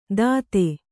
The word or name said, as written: ತದೆ